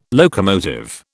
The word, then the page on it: locomotive: British English